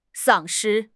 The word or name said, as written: 丧失